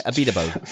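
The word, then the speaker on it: Abiteboul: Did I pronounce that right? I never get it right. Abidebo. – Fabulous pronunciation. Fabulous.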